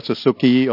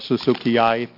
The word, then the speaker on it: suzukii: Thank you for the opportunity to present to you an update on the Drosophila suzuki or suzukii, whichever you want to pronounce it, an update on this invasive species.